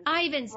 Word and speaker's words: Ivins: And the first is Patty Ivins. Uh, Patty, let me, am I pronouncing your last name correctly? You're so close. It's Ivan's. Ivan's, Long darn.